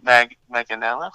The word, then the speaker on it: Manganiello: Big Dick Richie, Joe Manganiello. That's how you say it.